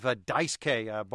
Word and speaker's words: Daisuke: Daisuke, D-I-C-E-K, capital letter K. Mm-hmm. Well, that's just a phonetic spelling of how you pronounce his first name.